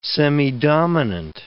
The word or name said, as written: semidominant